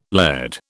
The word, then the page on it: laird: British English